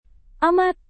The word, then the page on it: amat: indonesian